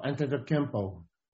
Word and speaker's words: Antetokounmpo: Oh, Antetokounmpo.